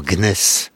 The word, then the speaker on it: gneiss: Vous dites dans le livre que toutes ces roches qui ont des noms magnifiques, comme je l'ai dit, se sont finalement transformées en gneiss. Est-ce que j'ai bien prononcé? – Tout à fait.